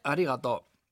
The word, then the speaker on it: arigato: Pues se dice arigato. Arigato, arigato. Practica por lo bajo poniendo el acento en la segunda A, lo que hace que suene muy gracioso, así que no le digo nada.